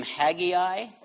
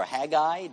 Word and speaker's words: Haggai: One guy named Haggai, or Haggai, depending on how you say it.